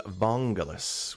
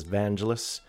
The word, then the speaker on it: Vangelis: Behind me, this is Vangelis, which some folks may pronounce as Vangelis.